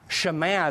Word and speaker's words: Shema: That's the famous Shema. Shema is actually how it should be pronounced.